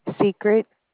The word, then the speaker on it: Secret: Secret. – Would you spell that? – S-E-C-R-E-T. – S-E. – C-R-E-T. – S-E-C-I-E-T.